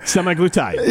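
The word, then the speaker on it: semaglutide: Well, after we found out the correct pronunciation, I have no more puns for semi-glutide. – Yeah, tied. – Yes. – Not tight.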